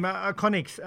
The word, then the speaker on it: Iconyx: And your name, Iconics, is that the right pronunciation and how do you spell that? – Yeah. Iconics, Iconyx.